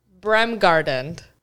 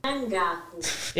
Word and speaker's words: Bremgarten: Bremgarten. – That's the American way to say it, but the German way was... – Bremgarten. – Yeah, there you go. That's how you say it.